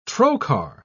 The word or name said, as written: trocar